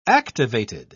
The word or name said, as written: activated